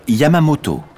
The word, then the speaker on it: Yamamoto: S-C-H-I-N. – Trois. – Je m'appelle Yamamoto. – Comment ça s'écrit ? – Y-A-M-A-M-O-T-O.